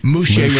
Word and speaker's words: Mouche: Sergeant, what is your last name? – Mouche. – With an M. Dan did screw it up. He has an N like November.